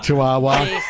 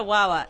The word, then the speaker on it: chihuahua: You say chihuahua. It's not a wawa. Chihuahua.